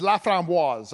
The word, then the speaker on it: Laframboise: But uh, uh, my name is uh, my last name is Laframboise. That's the proper pronunciation of it.